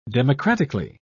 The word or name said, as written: democratically